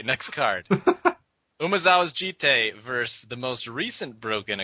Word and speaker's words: Jitte: Yeah, I've said this before on the podcast, but it's not pronounced Jit or Jitty. It's Jitte. And there's no, like, question about it either. It's not like, it could be pronounced this way. It's a fucking Japanese word, and that's how it's pronounced.